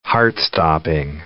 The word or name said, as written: heart-stopping